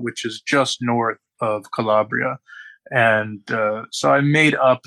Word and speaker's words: Calabria: And so is your family from, and make sure I'm pronouncing this right, Calabria? – Yeah, so Calabria is one branch of my family.